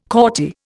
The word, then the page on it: courtier: Indian